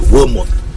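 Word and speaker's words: Wilmots: I say Wilmot.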